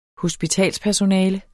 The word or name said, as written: hospitalspersonale